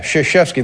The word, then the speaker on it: Krzyzewski: You know, that's an interesting, and it's just fascinating, and it shows you how ignorant you can be, but the name is Krzyzewski. – Yeah. – Yeah, Krzyzewski.